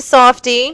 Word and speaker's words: Softe: It's Dave Softy, and it is the Metal Meltdown show. – Yeah, what is the last name again? Spell it. – S-O-F-T-E. – Oh, Softy, like Mr. Softy the ice cream.